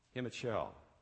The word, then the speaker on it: Himachal: I'm going to ask you how to pronounce that. What is it? Himachal.